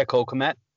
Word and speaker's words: Cole Kmet: You went Cole Kmet. Is that how you say his name? – Yeah, Cole Kmet.